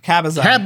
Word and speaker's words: Cabazon: The Cabanzo dinosaurs. Am I pronouncing that right? Cabanzo? – Cabazon.